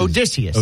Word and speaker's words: Odysseus: Odysseus.